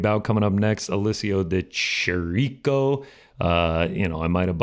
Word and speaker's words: De Chirico: I believe it is just De Chirico. – Yeah. Alessio De Chirico. There it is. Saying it properly.